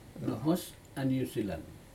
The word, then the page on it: nohos newzealand: Aneityum